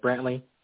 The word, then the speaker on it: Brantley: Facebook name is just Eric Brantley. The name is Eric Brantley on YouTube.